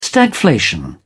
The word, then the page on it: stagflation: British English